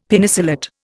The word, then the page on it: Penicillate: Indian